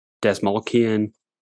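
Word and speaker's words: Dastmalchian: I always mispronounce his name. It's Desmalchian.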